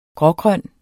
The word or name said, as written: grågrøn